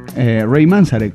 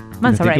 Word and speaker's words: Manzarek: yo quiero que vos me corrijas, o que lo digas vos de última, si sabes pronunciarlo bien, al resto de los Doors. – Ray Manzarek. – Manzarek.